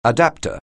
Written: adapter

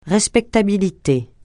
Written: respectabilité

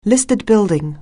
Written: listed building

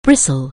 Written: bristle

